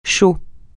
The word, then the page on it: chaud: français